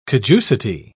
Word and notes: caducity: British English

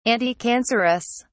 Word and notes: anticancerous: British English

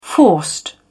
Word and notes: forced: British English

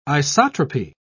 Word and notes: isotropy: British English

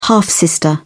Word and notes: half-sister: British English

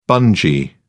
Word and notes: bungee: British English

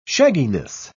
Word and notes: shagginess: British English

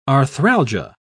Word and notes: arthralgia: British English

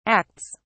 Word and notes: acts: British English

